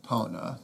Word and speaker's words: Epona: I think it's probably pronounced Epona, right? – Epona. That's how I say it.